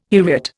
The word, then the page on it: heriot: Indian